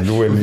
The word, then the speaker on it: Lewin: Also Desmond, jetzt weiß ich auch, wie spricht man denn aus? Lewin. – Ja, Lewin.